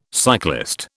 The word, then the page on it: cyclist: British English